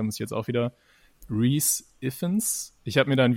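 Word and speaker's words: Rhys Ifans: Ja, ich sage immer Riz, aber ich glaube, es ist falsch. Riz. Ah, super, danke. Ja, dann, dann einigen wir uns auf Riss, Riz Iffens oder so.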